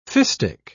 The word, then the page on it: fistic: British English